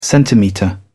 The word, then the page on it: centimetre: British English